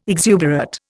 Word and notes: exuberate: Indian

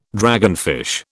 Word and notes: dragonfish: British English

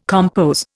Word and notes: compos: Indian

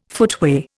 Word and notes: footway: Indian

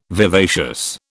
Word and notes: vivacious: British English

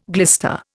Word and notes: glister: Indian

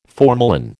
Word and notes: formalin: American English

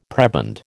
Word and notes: prebend: American English

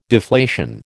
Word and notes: deflation: American English

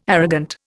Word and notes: arrogant: Indian

